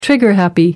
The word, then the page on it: trigger happy: American English